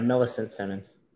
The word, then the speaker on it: Millicent Simmons: Yeah, Millicent Simmons.